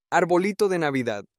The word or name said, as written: arbolito de Navidad